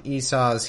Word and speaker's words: Esau: So E-S-A-U is Esau, like seesaw. But not as fun. "And after that came his brother out, and his hand took hold on Esau's heel, and his name was called Jacob.